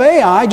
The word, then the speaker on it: Ai: In fact, that's how it's pronounced and that's how it's spelled, Ai.